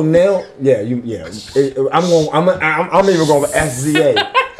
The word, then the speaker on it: SZA: SZA? Is that that's how you say it? SZA? Is that wow. – Yeah. – SZA.